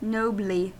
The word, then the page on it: nobly: American English